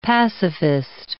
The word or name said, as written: pacifist